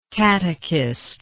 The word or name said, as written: catechistically